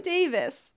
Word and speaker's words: Davis: Say his name again. – Jonathan Davis. – There you go. Oops. I thought you said Davies for a second.